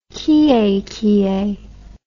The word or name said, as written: kiekie